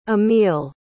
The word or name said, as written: emile